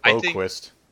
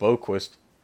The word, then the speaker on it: Boqvist: So at 7, Vancouver picked Quinton Hughes, defenseman, and at 8, Chicago picked Adam Bobqvist. I can't pronounce that. Also a defenseman from Sweden. – Looks like Boqvist. Looks like Boqvist.